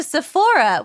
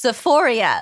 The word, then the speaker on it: Sephora: And Kimmy's sending him to Sephora, which he thinks is pronounced Sephoria, like the HBO show Euphoria.